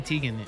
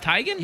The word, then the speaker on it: Teigen: Or I think it may be Teigen now. Remember we were pronouncing her name wrong? – Yeah, Chrissy Teigen. It's Teigen? – Yeah, it's Teigen.